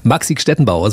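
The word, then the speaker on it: Maxi Gstettenbauer: Maxik Stettenbauer ist bei mir einer der besten Comedians Deutschlands. – Bravo, bravo. Sehr gut ausgesprochen. – Fehlerfrei ausgesprochen? – Perfekt, absolut. Ja.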